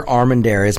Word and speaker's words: Armendariz: It's Victor Armendariz. Make sure you spell that name correct. A-R-M-E-N-D-A-R-I-Z.